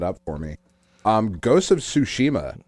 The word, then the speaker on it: Tsushima: So, you know, now I think the way you pronounce it is Ghost of Tsushima. – I'm sold on that being the pronunciation.